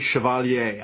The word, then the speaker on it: Chevalier: And I pronounce it the French way, Chevalier.